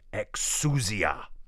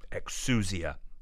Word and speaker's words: exousia: Now, the word authority is the Greek word exousia, exousia. It's spelled E-X-O-U-S-I-A, exousia.